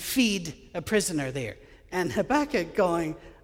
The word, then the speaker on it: Habakkuk: Looks like Habakkuk, but it's pronounced Habakkuk, trust me.